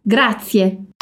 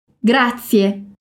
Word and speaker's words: Grazie: E a pronúncia parece com a pronúncia de um T e um S juntos. Então, repete comigo. Grazie. Grazie.